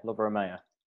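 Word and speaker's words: Lovro Majer: But today, this morning, we've been linked with a player called, please, I'm really sorry about this, Lovro Mayer. Is that right? – Yeah, Lovro Mayer.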